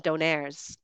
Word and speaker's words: donair: In Europe, it seems they call it more doner kebab, like doner, where in Canada we actually call them donairs, so donair. So we pronounce it a little bit differently. I'm not sure why that is, but I just noticed that in Canada we call them donair. And in Europe, they call them doner.